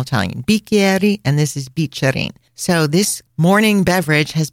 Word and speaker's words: Bicerin: When you see the letter C followed by an E, it's pronounced Che. So Bicerin.